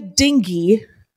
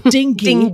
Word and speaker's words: dinghy: He tells everyone, quote, "I saw a bear rowing a dinghy." I remember he pronounces the hard, he pronounces the G hard like that. A dinghy.